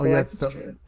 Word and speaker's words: Galactus: Cressy and the, is it Galactus? I want to add another syllable for some reason. – Oh, Galactus, yeah, the Ultimate Galactus trilogy.